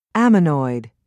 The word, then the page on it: ammonoid: American English